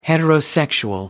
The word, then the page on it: heterosexual: American English